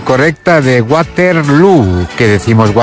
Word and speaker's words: Waterloo: Que además te van a dar además la cosita de, oye, y además te tienes que venir aquí a humillar literalmente a Waterloo, pero es que esto no te puede sorprender, pero bueno. – Ignasi. – Bueno, lo primero, Waterloo se pronuncia igual que Feijó.